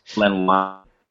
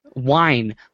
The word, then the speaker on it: Len Wein: Did I pronounce it wrong again? Len Wein? I can never get this right. – Len Wein. – Wine. Len Wein? Damn it.